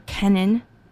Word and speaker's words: Kennan: Before that, more new music from Kate Keenan, or Kennan. Her last name is spelled K-E-N-N-A-N.